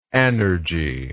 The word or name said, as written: anergic